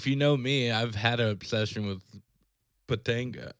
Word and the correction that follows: Topanga: is that how you say it? Patanga? – I've said her name wrong for 25 years. – If Boy Meets World, Danielle Fishel's the real name. I got a couple of posters. – I call her Topanga.